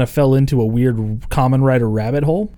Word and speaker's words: Kamen Rider: Kamen Rider, like Ramen Rider, but with a K.